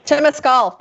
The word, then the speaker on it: Temescal: Am I saying that right? Temescal? – Temescal, yeah. – Not Temescal. – You're doing good.